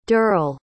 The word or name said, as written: derl